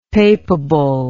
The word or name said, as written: papable